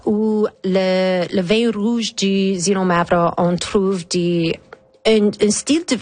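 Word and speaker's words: Xinomavro: Et parlons aussi d'un autre grand cépage grec qui s'appelle le Zinomavro. J'espère que je prononce bien. – Oui, c'était parfait. – Il vient d'où? – Zinomavro, on trouve plusieurs sur la région du Pelonésie.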